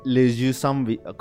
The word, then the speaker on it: Les Yeux sans visage: Pero ahora sí, habla, hablemos de Les Yeux sans visage. ¿Cómo se dice en francés, señor letrero? – Les, yeux sans visage.